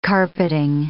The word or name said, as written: carpeting